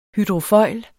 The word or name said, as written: hydrofoil